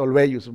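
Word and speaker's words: Olweus: el término bullying lo acuñó un personaje, un psicólogo, psiquiatra eh, noruego, que se llama Dan Olveius Se escribe así o l w e Se pronuncia Olveius un tema ahí medio raro de, idioma, ¿no?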